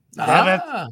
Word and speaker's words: Devitt: That one name I can pronounce is Prince Deviate. – Devitt. Yeah, Prince Devitt. – I know. – Finn Balor. – The other one was Ryushi, R-Y-U-S-U-K-E.